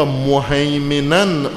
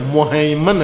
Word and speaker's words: مہیمن: لما بین یدیہ من الکتاب و مہیمناً علی، اور قرآن کا ہیمنا ہے، مہیمن ہے، مہیمن، تلفظ ہو جائے گا انشاءاللہ. مہیمن، مہیمن، مہیمن کہتے ہیں جس کا ہیمنہ ہو، تسلط ہو، سایہ ہو.